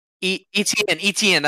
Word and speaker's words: Etienne: Etienne. That's what it is.